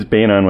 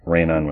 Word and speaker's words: Beynon: Right, and it is Beynon with a B, not Rainon with right, an R, because yeah.